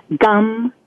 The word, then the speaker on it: Gam: Gum is a seed syllable, G-A-M, and it's pronounced gum, G-U-M. Ganapataye, that's G-A-N-A-P-A-T-A-Y-E.